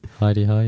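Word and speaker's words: Hi-de-Hi: Heidi High, yeah.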